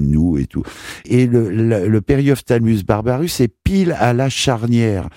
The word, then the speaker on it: Periophthalmus barbarus: Donc c'est pour ça que j'ai voulu faire un parallèle entre la Periophthalmus barbarus. En plus, quel nom, Periophthalmus barbarus.